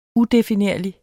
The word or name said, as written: udefinerlig